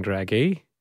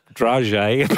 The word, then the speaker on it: dragée: The Australian way to say dragée is dragée, not dragée.